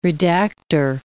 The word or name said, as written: redactor